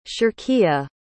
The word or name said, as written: shirkia